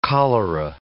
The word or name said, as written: cholera